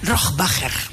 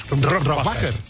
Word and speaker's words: Rohrbacher: Rohrbacher. – Rohrbacher.